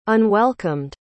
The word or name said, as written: unwelcomed